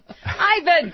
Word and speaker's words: Ivan: Is it spelled I-V-A-N? – Dias. Right. – That's pronounced Ivan. You said Evan.